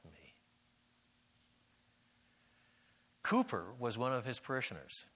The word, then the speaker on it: Cowper: Cowper's Grave, spelled Cowper, C-O-W-P-E-R. He pronounced it Cowper.